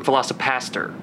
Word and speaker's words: VelociPastor: Velociraptor, or not Velociraptor. I said that wrong. VelociPastor.